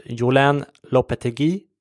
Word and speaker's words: Julen Lopetegui: Annars har ju den största transfern den senaste tiden varit att Real Madrid har anlitat Julen Lopetegui, som det uttalas tydligen, som jag har lärt mig på nätet, som tränare inför nästa säsong då, vilket gjorde att Spanska fotbollförbundet lackade ur och sparkade honom från förbundskaptenposten.